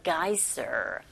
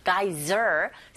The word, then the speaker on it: geyser: 빈칸에 들어갈 말이 a geyser. Geyser. 쓰 발음을 z로 하셔야 돼요.